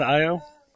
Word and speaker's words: Io: Isn't that Io? – It depends. – Io. – It gets very confusing. Some people pronounce it EO. Some people pronounce it IO.